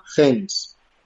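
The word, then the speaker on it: Hennes: Cuando se fundó en Suecia, en 1947 se llamaba Gens. Bueno, se escribe Genes, con H, que en sueco significa para ella.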